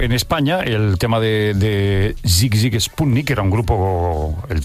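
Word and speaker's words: Sigue Sigue Sputnik: Sí, lo que pasa que era Zig Zig Sputnik, la pronunciación, y el tema Love Missile F1.11.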